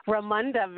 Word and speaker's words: Ramunda: Ramunda, very, very close.